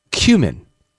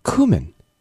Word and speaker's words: cumin: According to the Cambridge Dictionary, the UK pronunciation of the word is cumin. But in the US, it's pronounced cumin.